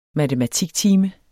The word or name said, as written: matematiktime